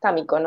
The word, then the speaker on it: Tamiko: De hecho, a mí me pasó, mi nombre es Tamiko, ¿no? Y para empezar, nadie lo pronuncia como tiene que ser, porque es un nombre japonés. Entonces aquí es como Tamiko.